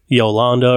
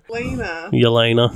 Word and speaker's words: Yelena: Yolanda or whatever her name is. – Yolanda. – I'll just call her Black Widow sister. Black Widow sister. – Yelena.